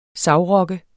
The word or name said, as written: savrokke